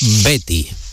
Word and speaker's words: Betis: ¿Cómo ha pronunciado usted, cómo ha aprendido a pronunciar Betty, siendo de la provincia de Gerona, de Girona, de Olot? ¿Cuántas clases particulares dio para pronunciar correctamente Betty? – Pues unas cuantas. Y esto fue a partir de escuchar pues el acento andaluz, ¿no? Y de ver que la gente no decía el Betis, sino que decía el Betty.